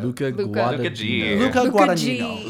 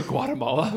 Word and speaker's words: Guadagnino: Guadagnino. The G is silent. Not all. One of the G's is silent. There are. – You said Luca Guatemala.